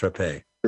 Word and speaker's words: Rappe: By the way, her name was Rappe. It's pronounced Rappe.